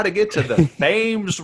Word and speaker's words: Thames: The Thames. I was on mute. Thames.